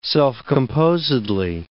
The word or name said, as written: self-composedly